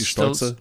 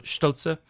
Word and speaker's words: Stolze: With if it's German, it would be Stolze. – Stolze.